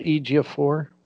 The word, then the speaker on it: Ejiofor: Yeah, like you said, I mean, it's got Elizabeth Olsen, Benedict Wong, Rachel McAdams, Chiwetel Ejiofor.